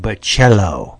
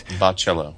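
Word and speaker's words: Bachalo: I've been saying it wrong, as is the case. It's not Bacallo. He pronounces it Bacallo. And so that's how we're going to do it. – So it's like, it's like Grabule Ba and then Cello.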